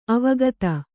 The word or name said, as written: ಅವಗತಿ